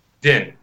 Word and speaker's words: Dinh: Dinh, that's right.